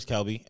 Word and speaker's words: Kelby: Somebody called me Kerbin today. – Well, you call yourself Kerbin. – No. I'll say, like, my name's Kelby, and they'll be like, okay, Mr. Kerbin.